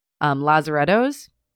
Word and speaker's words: Lazarettos: so they created what is called Lazarettos. I'm hoping I'm saying that right. – It sounds right. I saw the spelling. It sounds like the correct pronunciation.